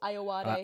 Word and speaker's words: Ayoade: Oh, Ayoade.